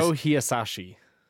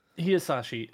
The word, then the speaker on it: Hisaishi: Joe Hisaishi. – Hisaishi.